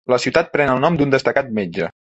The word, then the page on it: destacat: catalan